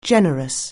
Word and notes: generous: anglais

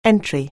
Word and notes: entry: anglais

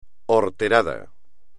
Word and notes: horterada: espagnol

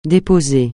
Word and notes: déposer: francais

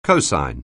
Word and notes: cosine: anglais

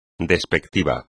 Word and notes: despectivo: espagnol

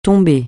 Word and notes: tomber: francais

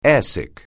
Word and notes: airsick: English

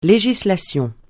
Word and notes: législation: francais

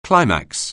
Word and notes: climax: anglais

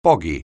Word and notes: boggy: anglais

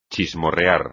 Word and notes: chismorrear: espagnol

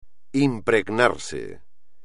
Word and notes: impregnarse: espagnol

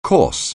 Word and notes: coarse: anglais

